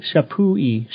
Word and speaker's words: Champoeg: Yeah, Champoeg, Shapuni. It's pronounced Shapuni, Shapui, Shapui. Sorry, the G is silent.